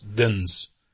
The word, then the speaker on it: Dins: I emailed him and asked him to phonetically spell or give me a rhyming word. – Yes. – And he said, he just spelled it as it sounds. It's Dins.